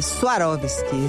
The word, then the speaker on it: Swarovski: Ela fala Swarovski, isso é a pronúncia correta aí para quem quiser ser precioso.